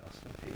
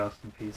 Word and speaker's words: Peay: Everybody I've heard say P, so I just say Austin P.